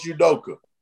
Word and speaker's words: Udoka: But Udoka. – Huh? – Coach Udoka.